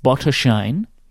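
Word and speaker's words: Botoșani: It's a place called Bottershine, it is called. It is spelled Bottosani. – Yeah, I would not have gotten that pronunciation from those letters.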